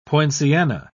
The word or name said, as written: poinciana